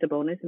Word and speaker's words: Sabonis: I think, is it Sabonis? Am I saying his name right? – Mm-hmm.